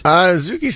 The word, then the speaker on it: Ozaukee: O-Z-A-U-K-E. Ozaukee? Ozaukee counties? – Ozaukee sounds good.